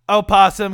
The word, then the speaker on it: opossum: Opossum this can be pronounced segment. With its first syllable either voiced or silent, according to Merriam-Webster.